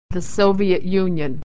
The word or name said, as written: the Soviet